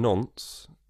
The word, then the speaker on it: Nantes: You studied in Nantes in France. – Did. – Is that the correct pronunciation? – Yeah, exactly. No, you did that very well because everybody else says Nantes or...